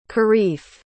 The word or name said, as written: karif